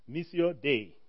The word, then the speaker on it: Missio Dei: I tried to look for how to pronounce that, and I found 10 different ways of doing it online. So I'll pronounce it like a proper African, Missio Dei.